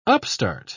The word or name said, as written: upstart